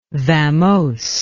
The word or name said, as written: vamose